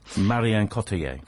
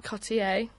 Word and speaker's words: Marion Cotillard: And she in this, played by Marion Cotillard. I don't know if I'm saying her name right. – Marion Cotillard. – Cotillard. Yeah, saying it wrong.